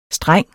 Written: STRENGENES

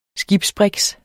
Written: skibsbriks

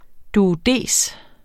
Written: duodes

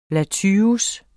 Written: latyrus

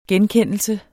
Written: genkendelse

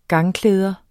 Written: gangklæder

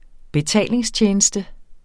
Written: betalingstjeneste

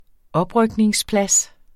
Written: oprykningsplads

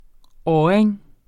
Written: ådring

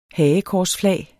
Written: hagekorsflag

